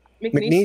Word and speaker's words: McNeese: Speaking of which, McNeese State, I'm pretty sure I'm not pronouncing that right. – You are. That's right. – McNeese State.